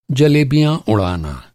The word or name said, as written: जलेबी